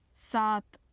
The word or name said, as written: saat